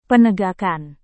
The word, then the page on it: penegakan: Indonesian